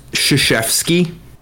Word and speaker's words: Krzyzewski: Pronounced Krzyzewski.